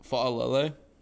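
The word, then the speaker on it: Faalele: Yeah, that's Shalali. What I was talking about. – How do you pronounce his name? Shalali? – Fa'alele. – Oh, there you go.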